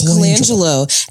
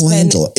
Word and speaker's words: Colangelo: It's Colangelo. And it's Colangelo. – It's written phonetically in front of me, BJ. – Patrick, it is pronounced like it's spelled.